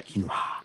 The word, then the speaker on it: quinoa: Quinoa.